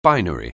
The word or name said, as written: binary